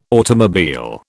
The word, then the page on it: automobile: British English